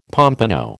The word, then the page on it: pompano: American English